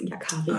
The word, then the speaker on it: Yakari: Ja, genau, das ist Yakari.